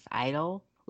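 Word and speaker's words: (G)I-DLE: Well, apparently we're saying it wrong. It's not G Idol. It's pretty much pronounced idle, with the G being silent.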